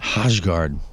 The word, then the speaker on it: Hojgaard: Nikolai Hojgaard. – Javi Bulin? – Hojgaard. – Spell that, please. – H-O-J-G-A-A-R-D.